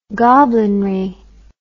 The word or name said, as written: goblinry